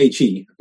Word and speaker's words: He: It's spelled H-E, but pronounced He in Mandarin.